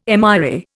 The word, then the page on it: MRA: Indian